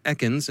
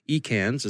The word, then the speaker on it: Ekans: By the way, a quick note, it's been brought to my attention by a kind listener that the correct pronunciation is Ekans and not Ekans, as I was saying earlier. Evidently, it's a Pokemon thing, and I appreciate the correction.